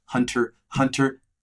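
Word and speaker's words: Hunter × Hunter: Yes, there is an X, but it's pronounced Hunter Hunter.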